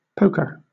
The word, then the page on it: poker: British English